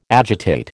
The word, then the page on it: agitate: American English